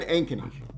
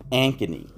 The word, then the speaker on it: Ankeny: We're not actually in Des Moines, but how do you say the name of this town? – We're in Ankeny. – Ankeny.